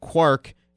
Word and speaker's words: quark: For instance, the coiner of quark in the physics sense had intended it to rhyme with cork, but general usage has resulted in it rhyming with mark.